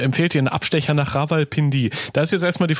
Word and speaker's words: Rawalpindi: Ähm, ja, war schon fast richtig. Rawalpindi ist, glaube ich, so ein bisschen schneller, ja. Ähm, okay. – Genau, Rawalpindi ist eigentlich so der chaotische Speckgürtel von Islamabad.